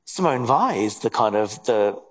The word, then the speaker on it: Weil: She was her surname is spelled W-E-I-L, but yeah, pronounced Weil.